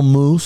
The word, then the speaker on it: mousse: I don't know, caramel mousse, swirl mousse. Have you had M-O-U-S-S-E? It's pronounced the same, spelt differently, okay?